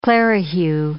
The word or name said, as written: clerihew